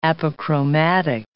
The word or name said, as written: apochromatic